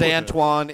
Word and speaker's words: Antawn: His name is Antoine. It's spelled A-N-T-A-W-N. – Yeah. – So he went by Antoine.